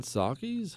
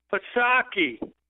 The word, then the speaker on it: Psaki: Jesse, I'm sick of you radio guys saying it wrong. It's Psaki. You got it? You know who I'm talking about? That redheaded... Oh, God, she's homely. Psaki. Say it right. Psaki.